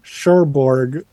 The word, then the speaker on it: Cherbourg: Sherborg. So it's spelled... – I'll look it up. – Share and then Borg with a U in it.